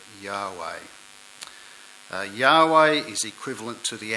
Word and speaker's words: Yahweh: And at the top of the slide there you can see the Hebrew characters, and they are transliterated into the Roman characters YHWH, generally regarded as being pronounced as Yahweh.